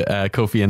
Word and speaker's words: Ko-fi: I thought it was Ko-Fi because it's like a pun on coffee. Buy me a coffee. – That's right. I actually went onto Kofi Annan's Wikipedia page when I was first searching for the service, which explains a lot.